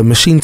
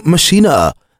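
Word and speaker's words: Maschine: The Machine 2 or Machina, if you're German, I think that's how they say it.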